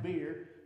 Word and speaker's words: bier: King James says B-I-E-R, pronounced beer, but it's what they were carrying, that coffin, that casket.